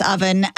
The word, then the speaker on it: Ovven: His name's Owen, but it's spelled O-V-V-E-N. It's pronounced oven.